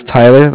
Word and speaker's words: Tyler: My name is Tyler, like I said, T-Y-L-E-R, and I'm calling with Cardiff. It's spelled C-A-R-D-I-F-F, as in Frank.